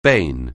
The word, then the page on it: bane: anglais